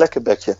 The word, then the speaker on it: Lekkerbekje: Lekkerbekje or kibbeling? – Kibbeling, yeah, kibbeling and lekker bekje. – Oh, Lekkerbekje.